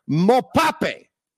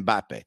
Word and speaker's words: Mbappe: He goes, watch this soccer player, Mopape, which is Kylian Mbappe. But anyway, he wasn't wrong with him, even though he got his name wrong.